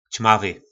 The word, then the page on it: Cmavy: Po našimu